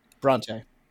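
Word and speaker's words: Brontë: So this is my first ever time reading Charlotte Bronte. Bronte? That's how you say it? – Bronte. Yep.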